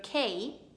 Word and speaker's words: key: Your key? Assolutamente no, è key, la giusta pronuncia di chiave, e al plurale avremo keys.